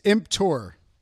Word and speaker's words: emptor: It's emptor. – Damn it. – Well, it's spelled E-M-P-T-O-R. – T-O-R, yeah.